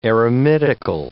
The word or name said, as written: eremitical